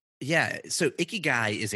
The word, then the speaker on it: Ikigai: I've seen these Venn diagrams before, and I'm pronouncing that correctly now. Ikigai, correct? – You are.